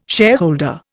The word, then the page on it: shareholder: Indian